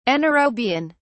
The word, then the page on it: anaerobian: British English